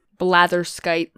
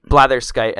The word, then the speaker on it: Blatherskite: How is that pronounced again? Blather? Blather? – Blatherskite. – Blatherskite. And that's Blatherskite. So go check that out.